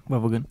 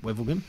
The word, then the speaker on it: Wevelgem: Wevelgem? – Wevelgem. – Wevelgem.